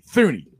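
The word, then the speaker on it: Thuney: Let's say the Bengals sign Tooney. Or excuse me, Thuny. I gotta say his name right. It's Thuny, not Tooney.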